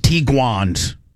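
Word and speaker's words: Tiguan: we're getting one of these Tiguans.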